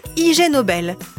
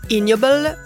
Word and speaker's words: Ig Nobel: Ce prix, c'est le IG Nobel, qu'on peut aussi prononcer ignoble comme ignoble.